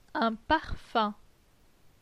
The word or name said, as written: Un parfum